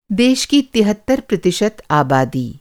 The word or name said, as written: तिहत्तर